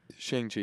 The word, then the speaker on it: Shang-Chi: Shang-Chi, yeah.